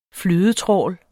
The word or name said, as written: flydetrawl